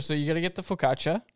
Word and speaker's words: focaccia: So it's focaccia. – Yeah, absolutely. A double C in the I is a sound.